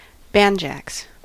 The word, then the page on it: ruinieren: [ʁuiˈniːɐ̯n]; /ʁuiˈniːʁən/